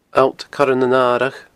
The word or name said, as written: alltcoirenanaradh